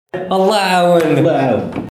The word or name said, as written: que dieu te vienne en aide